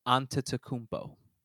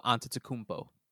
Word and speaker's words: Antetokounmpo: Antetokounmpo. – Antetokounmpo? – Antetokounmpo. There's an M before an M as in Mike before the P. So Antetokounmpo.